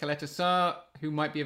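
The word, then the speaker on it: Ćaleta-Car: Our listeners and viewers last night sort of convinced us that it was Coletta Sarr.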